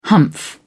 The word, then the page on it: humph: British English